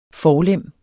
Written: forlem

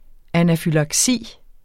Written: anafylaksi

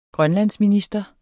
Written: grønlandsminister

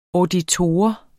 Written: auditor

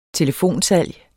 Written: telefonsalg

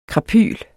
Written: krapyl

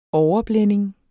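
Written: overblænding

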